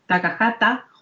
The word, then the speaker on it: Takahata: Takahaka no, ya, he dicho mollón de sílabas. No, lo, lo he dicho bien. Takahaka, Takahata ya se puede llamar James o algo.